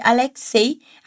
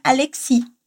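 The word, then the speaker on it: Alexeï: Si vous estiver acompanhando pela transcrição, vai notar que o nome de Alexei foi grafado em francês com trema no i. Isso acontece para que o nome seja pronunciado corretamente, já que sem a trema, ao invés de Alexei, a gente diria Alexi.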